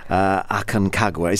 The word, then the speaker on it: Aconcagua: Now, tell us about your experience climbing this Mount Aconcagua. Is that how you say it? – Yes, yeah. That's correct, yeah.